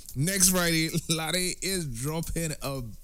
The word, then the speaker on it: Lahde: L-A-H-D-E. Lade is the pronunciation.